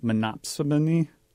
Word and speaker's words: monopsony: And the monopoly, there's a term in econ called monopsony.